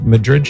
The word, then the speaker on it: Madrid: You're listening to The Patrick Madrid Show. My last name is spelled M-A-D-R-I-D.